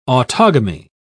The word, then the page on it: autogamy: British English